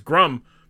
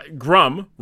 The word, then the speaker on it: Grumm: I mean, like, look, like my last name, I've said that it's Grum. It rhymes with cum. It's, Wait, what? – Grum rhymes with cum.